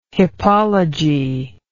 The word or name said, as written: hippology